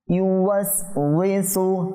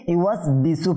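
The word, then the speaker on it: ইউয়াস উইসু (يُوَسْوِسُ): ইউয়াস উইসু। – আচ্ছা, এই শব্দটাকে অনেকে আমি শুনেছি ইউয়াস বিসু পরে, ইউয়াস বিসু, এখানে দেখেন ইউয়াস বিসু যদি কেউ পরে, ওয়াও জের উই,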